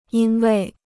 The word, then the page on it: 因为: yīn wèi